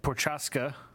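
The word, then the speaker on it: Procházka: Yeah, Prochaska.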